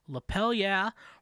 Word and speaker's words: Lapel Yeah: Lapel Yeah rhymes with hell yeah, as in give me a lapel yeah.